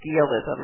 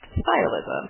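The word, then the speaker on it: sciolism: It doesn't, it again doesn't sound how it looks like it should sound, because it looks like it should be skialism or skyalism, because it's S-C, but it's pronounced stylism. – Like science. – Yes, like science, and they have the same root.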